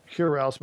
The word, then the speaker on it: Hurel: Yeah, so Hurel, but it's spelled H-U-R-E-L.